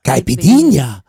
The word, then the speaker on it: Caipirinha: For a reason, because I can never say this right, and she's going to say it the way I wish I can say it half of the time. – Caipirinha.